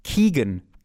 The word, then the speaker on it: Keoghan: Ich möchte vor allen Dingen kurz benennen, ich habe den Namen der Keanu, wie wird er ausgesprochen? Moment. Ich meine Barry Keoghan. K-E-O-G-H-A-N wird er genannt.